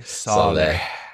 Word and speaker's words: Saleh: Say it with me. Saleh. – It's all about all right. The exhale.